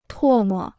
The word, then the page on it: 唾沫: tuò mo